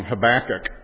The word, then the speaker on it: Habakkuk: If you're an American cowboy, you say Habakkuk. Habakkuk. That's how all of us Westerners say it. We get it totally wrong. Habakkuk, right? That's how I'm going to pronounce it this morning.